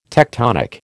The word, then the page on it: tectonic: American English